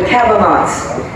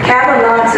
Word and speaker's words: Cabanauts: It's called The Cabanauts. And it is essentially a science fiction musical comedy. – Wow. – Cabanauts is spelled C-A-B, and the rest like astronauts.